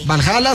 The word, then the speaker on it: Valhalla: A ver si me dicen si lo pronuncio mal. Valhalla. – Sí. – Valhalla Saltillo, así es, dije, no me voy a equivocar.